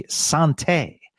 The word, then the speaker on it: santé: Cheers in French-Canadian is, I guess the proper pronunciation would be santé. So santé, Stars fans.